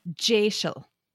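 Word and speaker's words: deiseil: And the word in Scottish Gaelic, I think, is D-E-S-I-L, but it's pronounced Jaisal.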